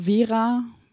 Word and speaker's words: Vera: Also wie mein Name Vera, also spricht man ja auch mit einem V vorne.